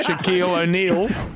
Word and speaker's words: Shaquille O'Neal: To a break because you chatted to Shaq Beal. – Oh, Shaquille. – Did I want to say it correctly? Yeah. Shaquille O'Neal.